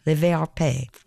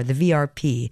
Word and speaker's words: Le VRP: From, well, I'll pronounce this both ways, Le VRP. That's as close as I can get in French. Or the VRP, as you might find it, from their album Retire les nains de ta poche.